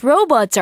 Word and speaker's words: robot: Whose robots are these? 이거 로봇 누구 거예요? 아, 이번에는 로봇이네요. 우리 로봇이라고 하지만 영어로는 robot 이렇게 발음하죠.